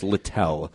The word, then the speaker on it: Littell: And is it Zach Littell? I believe it's pronounced Littell.